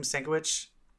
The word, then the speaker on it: Sienkiewicz: Sienkiewicz. Sienkiewicz.